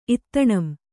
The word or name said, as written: ಇತ್ತಣಂ